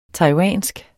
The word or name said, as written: TAIWANSKE